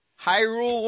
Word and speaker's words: Hyrule: Hyrule word? – Warriors. – Hyrule Warriors.